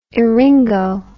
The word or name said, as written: eringo